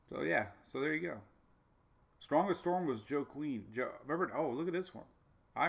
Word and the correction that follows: Joaquin: Joaquin. Joaquin. Not Joe Queen.